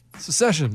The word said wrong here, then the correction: Succession: Am I not saying it right? – I don't think so. – Succession.